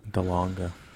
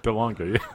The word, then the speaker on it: DeLonge: How do you pronounce it? – I don't know either. – Tom Wind. Wind. No. – Tom DeLonge. – DeLonge, yeah. – Yeah, that's how I say it.